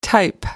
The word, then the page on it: type: American English